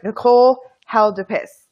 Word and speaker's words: Nicole Haldoupis: Nicole Haldoupis.